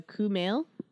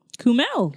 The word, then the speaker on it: Kumail: Oh, pro tip: my name is pronounced exactly how it's spelled. So Kumail. Kumail. Kumail.